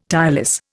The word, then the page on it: tireless: Indian